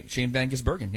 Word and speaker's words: Shane van Gisbergen: Well, Shane Van Gisbergen, or how do you say it? Shane Van Gisbergen. – Shane Van Gisbergen, yeah.